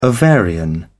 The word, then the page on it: ovarian: British English